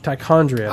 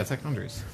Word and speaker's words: Tichondris: How do you spell it? – T-I-C-H... – Tichondris. – Oh, yeah, Tichondris. – I thought that's what he was saying, but he was saying a J in there, and I was like, I don't recognize that word.